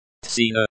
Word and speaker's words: Цена: Цена. Цена, say the ts as in tsar. The e at the end is pronounced like the in father.